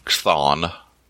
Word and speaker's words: Chthon: It's C-H-T-H-O-N, Chthon. Chthon is how I pronounce it.